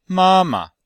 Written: ma1ma5

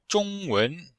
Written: zhong1wen2

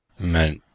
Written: men5

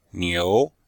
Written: niu2

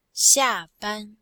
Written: xia4ban1

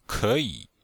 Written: ke3yi3

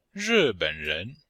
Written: ri4ben3ren2

